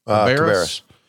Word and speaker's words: Cabarrus: How do you say this? Northwest Cabarrus High School? – Cabarrus. Yeah.